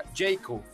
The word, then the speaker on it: Jaecoo: Eh, se escribe Jaeco. O sea, J-A-E-C-O-O, pero se pronuncia JQ, ¿correcto? Siempre van a oírlo como JQ, from Classic, Beyond Classic.